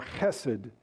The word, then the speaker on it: chesed: Kindness, hesed or chesed, if you want to do the Hebrew pronunciation, is a very hard word to translate because it is so full and rich.